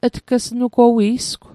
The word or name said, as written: tg'snugowi'sgw